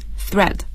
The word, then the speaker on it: thread: 这个 thread 是注意一下它的发音 th 这样的声音 thread。